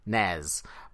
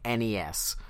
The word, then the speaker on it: NES: how to pronounce the acronym for the Nintendo Entertainment System, which most people would say NES, but they have come out and said no.